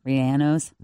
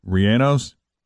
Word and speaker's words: relleno: Oh, Rianos. – Rianos? Chili Rianos. Is that how you pronounce it? – How do you spell it? – Chili, R-E-L-L-E-N-O.